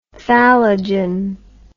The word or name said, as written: thallogen